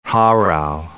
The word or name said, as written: Harald V